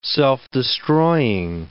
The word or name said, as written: self-destroying